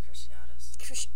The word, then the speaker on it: Cruciatus: Like, when Moody shows him the Cretaceous curse, and then, I don't think... – Oh, they pronounce it Cruciatus.